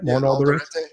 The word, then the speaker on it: Alderete: Juan Alderete, I hope I'm saying that right. – Juan Alderete. – Juan Alderete. Weirdly enough, comes from a metal band that some people may remember called Racer X.